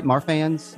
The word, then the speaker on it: Marfans: Marfans? – Yep.